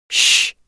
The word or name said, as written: hys